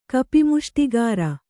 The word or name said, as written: ಕಪಿಮುಷ್ಟಿಗಾರ